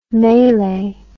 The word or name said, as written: mele